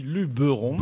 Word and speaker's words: Luberon: Nous restons dans une belle région où il y a plein de people, hein, un peu comme Jean-Philippe, en direction le Luberon, comme on dit. On dit Luberon ou Luberon ? – Non, on dit Luberon. – Il n'y a pas d'accent sur le « et ».